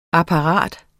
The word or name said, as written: apparat